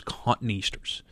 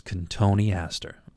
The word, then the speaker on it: Cotoneaster: Okay, we have customers all the time that come in and say, "I want one of those cotton easters." It's Cotoneaster. Okay, it's great. I love it when people say it, but that's how you pronounce it those wonderful, wonderful red berries all over that plant.